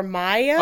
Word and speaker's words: Maja: So this came to us from, I'm not sure because you're Swedish, but it's M-A-J-A, which could either be Maja or Maya.